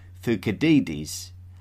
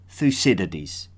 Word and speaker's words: Thucydides: In contrast though, I will refrain from calling the famous 5th century BCE Athenian historian Thucydides and opt for the more popular English pronunciation of Thucydides.